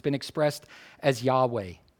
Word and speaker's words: Yahweh: In Hebrew, it's Yahweh, or at least that's how we pronounce it. There are no vowels in the divine name in the Hebrew text.